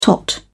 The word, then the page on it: tot: British English